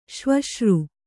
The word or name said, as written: ಸವಸರಿ